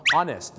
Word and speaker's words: honest: 3 คำที่เริ่มต้นในตัว H เนี่ยนะครับที่ไม่ออกเสียงฮอนะฮู้แต่เป็นออ่างหรือเป็นอ๊อเนี่ยนะฮะจะเป็นอีก 1ครับก็คือคำว่า honest นะครับ honest